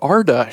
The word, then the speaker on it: Arda: We're taking our first trip to the world of Arda, Shane. I think it's pronounced Arda.